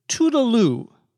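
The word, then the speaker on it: toodle-loo: Toodle, T-O-O-D-L-E, loo, L-O-O, is a funny informal way of saying goodbye nowadays. It's actually taken from a French word, but it is a very, we would call, corrupt pronunciation.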